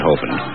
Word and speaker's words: Hovind: It reads, Dear Art, I was given a tape by Dr. Kent Hovind, and that is how it is pronounced, incidentally, Hovind.